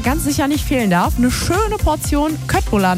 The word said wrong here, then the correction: Köttbullar: nach dem Shoppen oder auch Schöttbullar, so wird es ja richtig ausgesprochen.